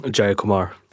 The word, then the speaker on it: Jayakumar: Jayakumar.